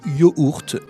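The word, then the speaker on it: yoğurt: En français, mais c'est également le cas en grec, cette lettre n'a pas été conservée dans la graphie, probablement parce qu'en turc, ce G avec un accent ne se prononce pas quand il est suivi d'une voyelle. Donc ça donne quelque chose comme yaourt ou yoourt en turc.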